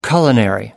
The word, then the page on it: culinary: American English